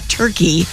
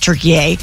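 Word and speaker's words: Türkiye: And I was surprised to see this morning that Turkey, or Turkey A, the way that I guess it's pronounced Turkey A, won 3-2, blocking us from completing that perfect World Cup start. We still advance. The knockout match is scheduled for Wednesday. I believe we play Bosnia. – Okay. I don't know why it just strikes me as funny that suddenly I'm hearing all the sportscasters say, Turkey A! When, like, in English, we pronounce it Turkey.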